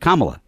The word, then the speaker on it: Kamala: That's how I think of that in my head, the Culture Club song, and it gives me the way to say it, Kamala. That's how you pronounce her name.